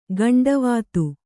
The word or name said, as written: ಗಂಡವಾತು